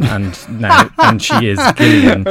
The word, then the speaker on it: Gillian: It stars, and I'm not pronouncing this incorrectly, Gillian Jacobs. She is Gillian,